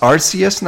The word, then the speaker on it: Arceus: It's actually pronounced Arceus now, which is weird because this Pokemon has had many official names or way to pronounce that name in the past. Like, I always thought it was Arceus before, and then there was an app, and in that app it was pronounced Arceus. And since then, I've always pronounced it Arceus.